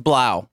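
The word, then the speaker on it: Blough: And then I don't remember, and maybe Stone, you can help if I'm pronouncing this man's name correctly. David Blau. – That's Blau. – Blau.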